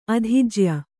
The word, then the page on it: ಅಧಿಜ್ಯ: kannada